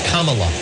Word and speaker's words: Kamala: I, Kamala Davy Harris, Kamala.